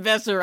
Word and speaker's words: Besser: David Besser rhymes with Lesser,